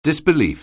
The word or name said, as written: disbelief